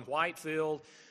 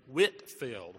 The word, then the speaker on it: Whitefield: Sometimes people call him Whitefield. I think the correct pronunciation from the two volumes of the biography I've read is Whitfield, where you didn't say the H, George Whitefield.